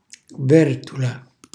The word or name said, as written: vertola